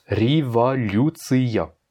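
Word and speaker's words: revolutia (революция): Tu vois le mot revolutia, la révolution, donc ça se prononce revolutia. Donc on a l'accentuation sur le you, revolutia, mais le ya, il n'est pas accentué, donc on serait tenté de le prononcer comme un i, sauf que non, parce qu'il est en fin de mot.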